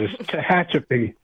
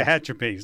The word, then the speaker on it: Tehachapi: It's Tehachapi. – Oh, thank you, thank you, and yeah. Tehachapi.